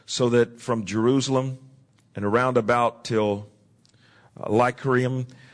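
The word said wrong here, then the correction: Illyricum: I have fully preached the gospel of Christ." Lyricum is the proper pronouncing.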